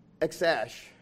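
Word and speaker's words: X Æ: He said in his own words, X is just pronounced X. And the A-E together is supposed to be pronounced Ash. So his first name is really X-Ash, which is weird.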